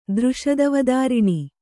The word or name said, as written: ದೃಷದವದಾರಿಣಿ